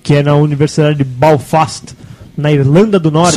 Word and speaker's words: Belfast: Belfast.